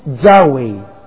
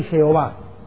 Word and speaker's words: Yahweh: Y dijo Yahweh. ¿Por qué digo Yahweh y por qué a propósito no digo ni Yahvé ni Jehová? Porque ninguno de los dos nombres está correctamente traducido, pronunciado.